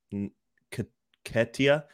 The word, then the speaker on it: Nketiah: Yeah, the guy that I'm really looking forward to on Arsenal's team is Eddie Nketiah or Nketiah. The N is silent, and he scored, I believe, twice in Europe this week.